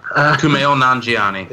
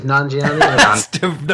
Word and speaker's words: Kumail Nanjiani: Kumail Nanjiani. – It is Nanjiani. – No hesitation at all, straight in there.